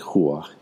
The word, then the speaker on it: ruach: In his first publication of the LBRP in 1932, Rigardi wrote that the sword to represent the dispelling critical faculty of the ruach, a Kabbalistic term for one of the levels of the soul, it literally means breath, huah, it's pronounced more like huah in modern Hebrew, is usually the instrument employed in this connection.